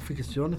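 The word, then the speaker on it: fiction: Giorni fa hanno fatto anche un documento, una ficzione. Ho detto bene? – Sì, sì, vedi che parlo spagnolo argentino, non lo voglio.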